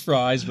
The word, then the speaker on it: Fries: It's spelled like French fries, but my family has always said Fries.